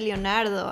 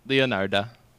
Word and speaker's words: Leonarda: Oh, I thought you said Leonardo. I'm like, what? – Nope, Leonarda.